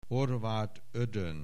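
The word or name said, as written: Horváth Ödön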